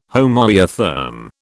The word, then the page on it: homoiotherm: British English